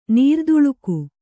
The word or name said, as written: ನರೆದಲೆಗ